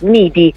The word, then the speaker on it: MIDJ: Certo, sicuramente sia il sito ufficiale che le pagine social Instagram, Facebook dell'Associazione Musicisti Italiani di Jazz, che viene abbreviata in MIDJ, MIDI, così si pronuncia l'acronimo.